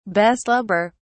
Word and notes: beslubber: British English

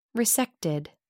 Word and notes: resected: American English

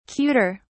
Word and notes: cuter: British English

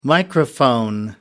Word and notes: microphone: American English

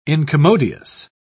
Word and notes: incommodious: British English

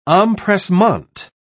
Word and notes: empressement: British English

